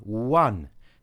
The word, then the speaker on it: one: Sounds like it begins with a W, w, w, one, so a one-year follow-up.